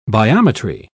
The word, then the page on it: biometry: British English